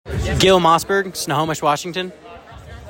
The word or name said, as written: Gil Mossburg